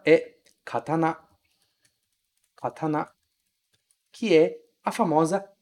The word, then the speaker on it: katana: A gente também não nasaliza muito esse A. Em português a gente fala katana, com esse an aqui, katana.